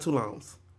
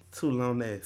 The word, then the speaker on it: Tulane: Yeah, so it's not Tulones. – No. – Tulones. – Tulane.